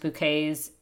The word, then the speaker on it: Bucket: But it made me think a lot about, from Keeping Up Appearances, hyacinth bouquet, bucket pronounced bouquets,